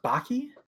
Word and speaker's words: Baki: Is that how it's pronounced? It's pronounced Baki? – Yeah. – Okay. – How'd you think it was? Baka? – I don't know. I don't. – Oh, okay. – Isn't it just B-A-K-A? – B-A-K-I.